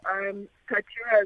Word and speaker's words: Inkaturah: Firstly, let's start by unpacking the name, Inkaturah. I hope I'm pronouncing it right. – Yeah, very much so. Inkaturah. – Mm-hmm. – So, Inkaturah is my second name, and that's how the brand name came to be.